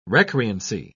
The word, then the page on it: recreancy: British English